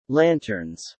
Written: lanterns

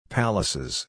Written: palaces

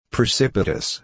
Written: precipitous

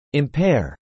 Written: impair

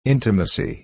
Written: intimacy

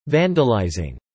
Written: vandalising

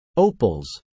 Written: opals

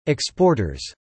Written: exporters

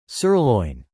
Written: Sirloin

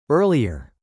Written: earlier

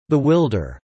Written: bewilder

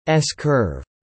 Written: s-curve

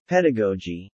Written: pedagogy

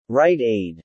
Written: Rite Aid

